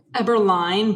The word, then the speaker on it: Eberlein: Quick note, Diana, do you pronounce it Eberlein or Eberlein? – Eberlein, but honestly, no one ever gets it right, so I just answer to whatever.